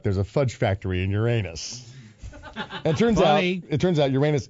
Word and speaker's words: Uranus: Uranus is how it's pronounced. – Uranus.